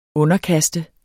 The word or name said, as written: underkaste